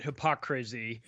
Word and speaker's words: Hypocrazy: Well, I've been pronouncing it Hypocrazy. – Yeah. – Just like the word hypocrisy, but I just changed the last word to crazy because that's what we're living in.